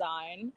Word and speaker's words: XYNE: It came out of nowhere, but it's pronounced Zine.